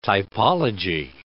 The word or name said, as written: typology